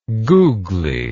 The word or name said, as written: googly